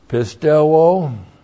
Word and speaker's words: pisteuo: Pisteuo, we had the noun pistis, now the verb. P-I-S-T-E-U long O, pisteuo.